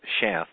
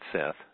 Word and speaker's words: Shath: That she was able to have Shath, not Seth. Shath is how it actually is spelled and pronounced.